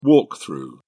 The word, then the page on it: walk-through: British English